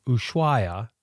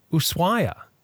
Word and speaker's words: Ushuaia: And the little destination town of Ushuaia is mentioned quite a bit in various episodes. In Spanish, he says, the H is silent. However, it's frequently pronounced with the SH sound.